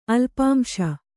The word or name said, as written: ಅಲ್ಪಾಂಶ